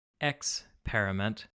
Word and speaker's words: Xperiment: Say it right. – Xperiment. – Thank you.